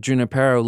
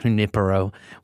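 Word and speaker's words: Junípero: That's my preferred pronunciation. You know, this is a California brand. This is not Junipero. We ain't out there in Spain. I'm sorry. I know it's the Spanish word.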